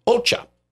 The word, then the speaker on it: OCCAA: The spelling of the, if you put the letters together, it's OCCAA. It is pronounced OCHA.